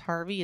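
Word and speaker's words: HRVY: They released a song called Don't Need Your Love, featuring the English singer, and I think it's pronounced Harvey, it's H-R-V-Y.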